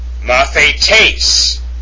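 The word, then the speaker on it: mathetes (μαθητής): Number five, mathetes is how that's pronounced, mathetes, and it's written mu, alpha, theta, eta, tau, eta, pardon me, sigma. Tony's got it right, and you would put an accent, acute accent, over the last eta, and then it would be pronounced mathetes, mathetes.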